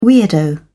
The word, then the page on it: weirdo: British English